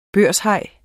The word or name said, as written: børshaj